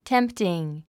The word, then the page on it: tempting: tém p tiŋ; テンプティン